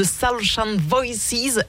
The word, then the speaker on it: Soulshine Voices: Une des nombreuses chanteuses et chanteurs de Soulshine Voices. Est-ce que je le prononce bien Oui, très bien. C'est exactement ça.